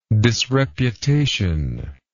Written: disreputation

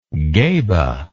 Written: geba

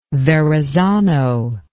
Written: verrazano

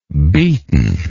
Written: beaton

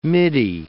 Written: middy

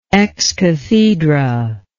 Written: excathedra

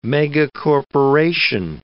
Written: megacorporation

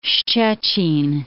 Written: Szczecin

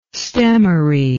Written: stemmery

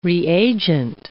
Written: reagent

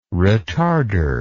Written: retarder

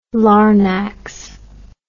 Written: larnax

